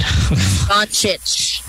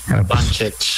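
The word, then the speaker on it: Dončić: Doncic. – Doncic.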